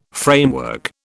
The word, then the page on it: framework: British English